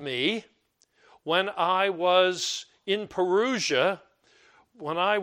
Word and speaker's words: parousia: The word present there is the word, well, in Greek it's pronounced parousia. We usually pronounce it parousia.